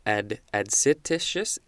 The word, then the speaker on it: adscititious: Next is adscititious, that is how it's pronounced, I believe, A-D-S-C-I-T-I-T-I-O-U-S, adscititious, adjective from 1620, derived or acquired from something extrinsic.